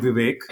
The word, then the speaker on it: Vivek: The Vivek is easier. Think about awake, and then you'll get Vivek.